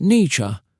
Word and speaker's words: Nietzsche: Philosophy Window, Nietzsche, and the Fire Within. Friedrich Nietzsche, pronounced Nietzsche, was a philosopher with wild ideas and an even wilder mustache.